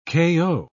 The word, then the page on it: kayo: British English